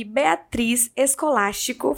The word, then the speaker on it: Beatriz Escolástico: Hoje eu recebo aqui Beatriz Escolástico.